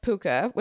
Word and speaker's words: Pooka: So the meaning of Pooka, which I have pronounced correctly, is Pooka.